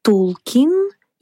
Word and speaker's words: Толкин: Но сам он указывал, что всегда произносит окончание как «кин». Так что вариант «Толкин» более точно отражает произношение фамилии. Некоторые члены семьи писателя даже ставили ударение на последний слог «Толкин».